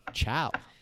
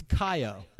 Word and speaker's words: ciao: And I'm like, well, ciao. And he's like, no. It's Kayo, because he was looking on Google Translate and read it as, you know, C-I-A-O.